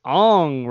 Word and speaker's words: Aang: And his name is pronounced Ong, right? – Yeah.